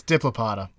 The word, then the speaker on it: Diplopoda: Diplopoda? I just like messing with your pronunciations of things. – No, it's Diplopoda for sure.